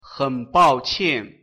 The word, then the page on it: desculpe: mandarim